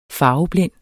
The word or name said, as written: farveblind